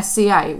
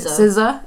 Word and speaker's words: SZA: I can't pronounce it. SCA. What is SZA. – It? SZA. Is that actually SZA? – Yeah, you know, like RZA. – Yeah, it's actually SZA.